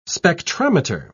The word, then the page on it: spectrometer: British English